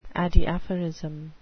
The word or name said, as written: adiaphorism